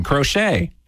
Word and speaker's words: crochet: The only thing more magical than being able to crochet is the silent T in crochet.